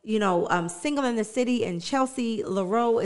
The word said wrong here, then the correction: Lamore: is that how you say it? – Lamore.